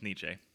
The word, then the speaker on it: Nietzsche: It's Nietzsche.